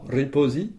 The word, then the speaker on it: ripozi: Riposi, c'est se reposer. Riposi, ça s'écrit R-I-P-O-Z-I. Rappelons que pour le son Z, c'est la lettre Z que l'on prononce zo en espéranto.